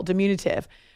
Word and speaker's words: diminutive: See, it's all about where you place the emphasis. Second syllable, diminutive.